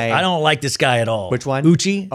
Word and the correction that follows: Uche: Oh Uche. Right? I always say his name. Oh wrong. yeah. U- Uche.